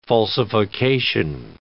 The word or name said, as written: falsification